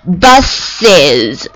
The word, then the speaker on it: buses: 好，第二种就是加 ES，它的读音永恒不变的，读 is。比如说公交车，一辆公交车 one bus，两辆公交车 two bus 加 es，它读作 buses，一定要学会啊。